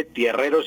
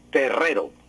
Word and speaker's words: terrero: Entonces no se dice tierrero, sino que se dice es terrero, un terrero.